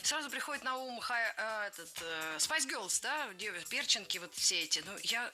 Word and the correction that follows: перчинка: Перченка, неверно. Правильный вариант – перчинка, с ударением на втором слоге.